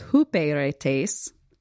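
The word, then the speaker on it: huperetes (ὑπηρέτης): We've got this is the one that, to me, it should be pronounced hyperetes. So if you're doing your own Strong's work, you can try to use that pronunciation. But apparently it's huperetes.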